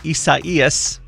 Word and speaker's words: Isaias: Watching tropical storm Isaias, that's how you say it apparently, is gaining strength.